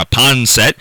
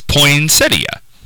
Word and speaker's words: poinsettia: No, and if somebody wants to call it poinsettia or poinsettia or a ponset, that's fine by me. You can call it whatever you want. I'm just saying that the correct pronunciation, which is what I was going for, is poinsettia.